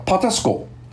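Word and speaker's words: Patapsco: 这条河的名字呢，一多半人呢都读不出来，叫 Patasko，它其实还有一个 P 字不读音的，叫 Patasko。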